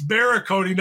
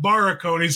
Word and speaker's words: Barakony: It's Barakony, not Barakony.